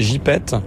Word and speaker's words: gypaète: L'une des actions que j'ai vues sur votre site, c'est notamment la réintroduction des gypètes. – Oui. – Je crois qu'on dit comme ça. – Oui. – Ça s'écrit gypaète, mais ça se dit gypète.